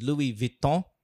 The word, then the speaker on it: Louis Vuitton: I think the correct pronunciation is Louis Vuitton. And is... – Walang N? – It's there. It's floating. It's very floating.